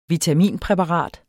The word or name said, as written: vitaminpræparat